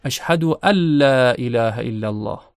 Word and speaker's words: اشهد ان لا: یکی از اونها در اذان و اقامه اشهد الا، اگر ادغام نکنیم اشهد ان لا، اما وقتی ادغام میشه نون در لام ادغام میشه و لام مشدد تلفظ میشه. اشهد ان لا اله الا الله، اشهد ان لا اله الا الله.